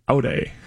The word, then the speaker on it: Ade: The pronunciation guide says Ode. – Ode. – Hmm. – O-W-D. – Well, then this guy on Twitter is just wrong.